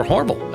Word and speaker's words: Hormel: introduced by Hormel, or Hormel, as the locals say.